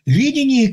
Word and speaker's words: видение: Видение, которое, я подчеркну, видение на первом слоге ударения, а не на втором.